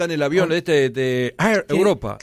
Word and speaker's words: Air Europa: Pronuncien como ustedes quieran. Yo le pronuncio acá en español. – Air. ¿Cómo es Marcela que conoce y llama bien? – Air Europa. – Bueno, ahí está. Air, querido. Claro.